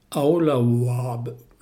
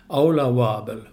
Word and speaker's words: Åla Vabbel: Han skriver att det dessutom ska det uttalas som ett engelskt W, som wow, sådär. Och ett långt A. Då blir det alltså Åla Vabbel.